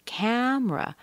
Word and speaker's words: camera: New, new, camera, camera. We're missing a syllable there. No E. Camera.